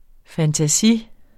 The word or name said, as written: fantasi